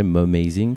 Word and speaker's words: Mumazing: On va en parler de, donc, de ton projet Mom Amazing, si je le prononce bien. – C'est le mélange de Mom et de Amazing.